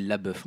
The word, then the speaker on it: LaBeouf: Alors, je ne sais jamais si on dit LaBeouf, LeBeouf. – Je crois que c'est LaBeouf. – Là, ça s'écrit LaBeouf. Moi, j'ai toujours dit Shia LaBeouf.